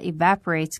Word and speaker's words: evaporate: Your sweat evaporates quickly，你的汗挥发的非常的快。 Evaporate 这个词是一个动词， E-V-A-P-O-R-A-T-E，我们一定要注意一下这个词的重读音节是在第二个音节上， evaporate。